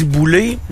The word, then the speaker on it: Boulet: Tu sais, on dit Boulay, mais ça s'écrit Boulette.